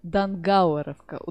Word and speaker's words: Дангауэровка: Неизвестная, а вот тут внимание, Дангауэровка. Ударение на А.